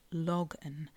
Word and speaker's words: Logan: Now, really, it should be pronounced Logan from the Cornish word log, which I am assured means to rock like a drunk person.